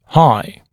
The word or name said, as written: high